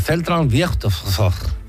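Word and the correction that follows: Zentralfriedhof: Que no lo he dicho bien. – Zentral Friedhof, creo que se dice o algo así. – Ahí te han faltado letras. – Es que luego yo me lo pongo, ¿cómo se dice fonéticamente? – Central Friedhof.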